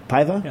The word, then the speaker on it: Paiva: Pedro, how do you think you pronounce P-A-I-V-A? Portuguese. I Paiva? – Paiva. – Paiva? – Yeah.